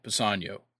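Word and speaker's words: Kpassagnon: Pisanio. Pisanian. Okay. I think I think that's right. I know I know that the K is silent.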